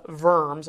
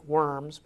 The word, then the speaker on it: Worms: sort of the famous Diet of Worms. It looks like worms, but it's pronounced Worms.